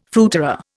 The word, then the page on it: fruiterer: Indian